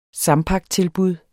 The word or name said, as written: sampaktilbud